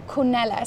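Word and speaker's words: Cornelys: So if you think about Bath and big parties, in the Georgian era, and Carlisle House was run by a woman called Teresa Cornelis, or Cornelis, some people say her name.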